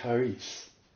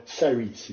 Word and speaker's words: charis: And he came up with the word charis. Charis. Or saris, if you wish. It depends which Greek phonetic pronunciation you wish.